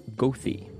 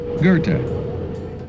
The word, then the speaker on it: Goethe: Many long-term residents call a particular street Gothy, but the CTA announcer calls it Goethe.